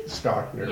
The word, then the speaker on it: Stockner: Stockner, yes.